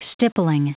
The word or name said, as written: stippling